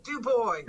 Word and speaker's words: Du Bois: How did they pronounce their name? – Du Bois. – Du Bois. Okay.